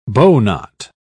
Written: bowknot

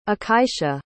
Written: akaisha

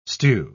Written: stew

